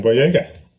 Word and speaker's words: Boyega: This movie also stars, and I honestly, I don't know how to pronounce this guy's last name, John Boyega? – John Boyega.